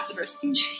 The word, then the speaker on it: Zabriskie: If you look at photos of the three of them together, she looks like Grace Zabriskie. Or is that how you pronounce it? – Yeah. – Zabriskie.